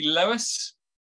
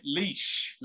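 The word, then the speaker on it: Laois: County Lewis? – Oh, Leash. No, Kildare I'm in. – Kildare. – Near Leash. – Right. Leash. That's how you pronounce it. – Yeah, no, not Lewis, I know.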